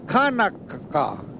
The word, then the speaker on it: Hanukkah: To pronounce the K, Hanukkah.